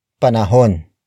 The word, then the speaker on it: panahon: It is the focus marker, indicating that the next word is the focus of this sentence, which is panahon. Panahon. Panahon. Panahon is spelled as P-A-N-A-H-O-N.